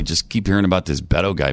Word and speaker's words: Beto: You mispronounced his name. It's Beto. – Beto.